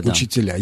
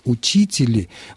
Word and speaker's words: учителя: когда сегодня слышат, мы слышим по радио, как кто-то говорит «учители», мы просто слышим архаичное ударение, но это не такая новация, которая способна вытеснить уже устоявшуюся форму учителя.